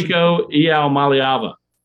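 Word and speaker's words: Nico Iamaleava: Nico Ealmaliava. – Ealmaliava. Well done.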